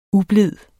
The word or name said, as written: ublid